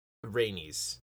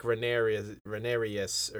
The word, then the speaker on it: Rhaenys: say her name wrong. – No, that was right. – Well, that time I did. I called her like Rhaenyrus or something earlier.